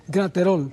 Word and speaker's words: Graterol: Bien, la primera pregunta que vamos a tocar en esta hora es de el hermano Josmar Great Roll, creo que así es que se pronuncia. – Graterol. – O Graterol.